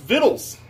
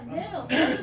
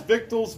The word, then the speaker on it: victuals: It's pronounced vittles. – Yeah, vittles. – All right. So vittles is biblical, guys. I just wanted you all to know that the word vittles... All right, it's otherwise pronounced as victuals, vittles, that's food.